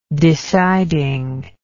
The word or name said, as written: deciding